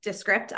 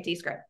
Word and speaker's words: Descript: Oh, and that's how you pronounce it. Descript. I've been calling it Descript.